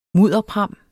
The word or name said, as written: mudderpram